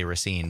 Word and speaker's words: Racine: how native Raciners pronounce it even. – Even other people around the state often say Racine, but right. Racine, if you live there, is how you say it.